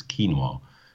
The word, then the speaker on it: quinoa: The quinoa is obviously how you pronounce it in Quechua, which is the language of the Altiplano. So all the indigenous people up in the Altiplano, about 4,000 meters, they all speak Quechua, and that's how you pronounce quinoa.